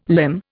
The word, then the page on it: limn: Indian